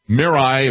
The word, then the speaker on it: Mirai: And then a couple weeks after that, the middle of October, they released this, they call it the Mirai, if I'm pronouncing it correctly. It's M-I-R-A-I.